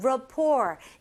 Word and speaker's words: rapport: Our good, 여기서는 rapport로 하지만 rapport. 이게 French에 이렇게 영향이 있어서 rapport가 아니라 rapport. T 발음이 없습니다.